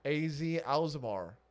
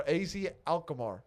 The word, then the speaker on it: AZ Alkmaar: AZ Alkmaar. – Is that how you say it? – No, AZ Alkmaar.